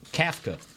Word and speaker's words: Kafka: So the offensive coordinator is Mike Kafka, K-A-F-K-A. Is that pronounced right? – Kafka.